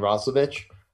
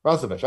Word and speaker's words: Roslovic: I don't think there's anyone that can match up against him and Roslovic. Roslovic. I said it right the first time.